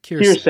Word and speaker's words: Quirce: So, I guess the one that comes to mind is I have no idea how to pronounce this. Kurice? Q-U-I-R-C-E? – Kirse. – Kirse, there you go.